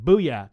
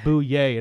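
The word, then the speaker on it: Bouyea: I keep wanting to call him Booyah because that's how it's spelled, but yeah. It is Bouye, and it throws me off every time.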